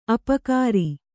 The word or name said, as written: ಅಪಕಾರ